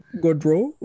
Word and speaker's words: Gaudreau: His name Gaudreau.